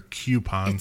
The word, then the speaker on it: coupon: Is it coupon or coupon? – It's coupon. – Coupon? – I hate when people say cute. It's not cute. It's not spelled with the cute. – Coupa. Coupon. – Like coupe. Coupon.